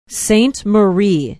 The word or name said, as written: Saint Marie